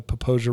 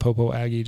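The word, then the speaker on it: Popo Agie: on the North Fork of the Poposia River, North Atlanta. I almost said Popo Aggie just to trigger all of the... – Because we've been making fun of the people who do say it wrong.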